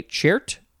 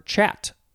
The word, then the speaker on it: chert: Next is, you could say chart or chat. It is spelled C-H-E-R-T, noun from 1679.